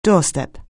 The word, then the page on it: doorstep: anglais